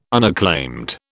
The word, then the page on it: unacclaimed: British English